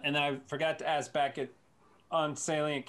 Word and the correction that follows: Salience: Capital V, is it Salient Capital? I feel like I'm saying it incorrectly. Salience or Salient with a T? – It's Salience, but I mean,